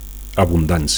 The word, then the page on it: abundancia: Catalan